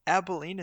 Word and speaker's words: Abilene: Texas does have way too much for Abilene. Is that the correct pronunciation? – Yeah. Yep.